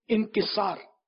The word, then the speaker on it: انکسار: عام طور پہ انکسار ہی بولتے ہیں لیکن صحیح تلفظ جو ہے نا لفظ یہ انکسار, عجزو انکسار یا عاجزیو انکسار.